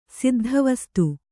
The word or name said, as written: ಸಿದ್ಧವಸ್ತು